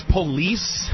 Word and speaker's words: Polis: Representative Jared Polis. Am I saying that right? – I think it's police.